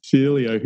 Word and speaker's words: Celio: Celio, who joins us today on the Super Dad Show for episode 35.